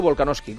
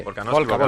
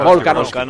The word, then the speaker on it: Volkanovski: ¿Volkanovski o Volkanoski, ¿Cómo se dice? – Volkanovski. Volkanovski. Perfecto.